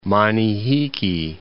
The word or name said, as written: Manihiki